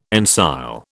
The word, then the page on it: ensile: British English